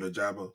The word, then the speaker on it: Ojabo: How you say Ojabo? – Yeah, David Ojabo.